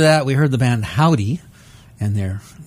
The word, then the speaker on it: Hovvdy: They spell their name H-O-V-V-D-Y. It's forming a W with the V's, but we're going to pronounce them Howdy.